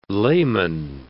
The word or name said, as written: layman